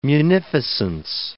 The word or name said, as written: munificence